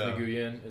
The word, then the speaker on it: Nguyen: That was after Miss Wynn left, right? – Yeah. – Miss Nguyen is how you pronounce it.